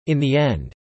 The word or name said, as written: in the end